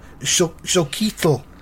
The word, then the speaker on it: Xochitl: And her first name is pronounced X-O-C-H-I-T-L. Zoquitl.